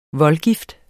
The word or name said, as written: voldgift